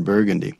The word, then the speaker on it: Burgundy: That's the New Orleans pronunciation of the word Burgundy.